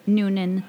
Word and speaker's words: Noonan: Did I pronounce the last name right? – Noonan, that's Noonan. – Correct.